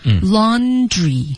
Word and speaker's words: laundry: laundry 第二的发音一定要撅嘴